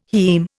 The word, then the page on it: haem: Indian